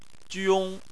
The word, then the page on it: jiong: 1